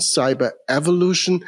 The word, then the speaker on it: Cyberevolution: Yeah, I think you already pronounced it accordingly. The word Cyberevolution obviously carries evolution and revolution in it.